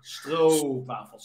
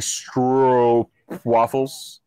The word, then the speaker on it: stroopwafels: It's a long O, so it's O, stroopwafels. – So you're saying stroopwafels? – Yeah, exactly. Stroopwafels.